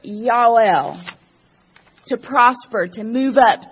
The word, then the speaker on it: Yael: Yael is how it's pronounced in Hebrew. Yael.